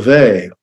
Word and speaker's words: Weil: I remember some time encountering the work of the French religious philosopher Simone Weil. If my pronunciation is confusing you, it's spelled W-E-I-L.